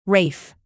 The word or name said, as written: rafe